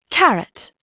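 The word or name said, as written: carrot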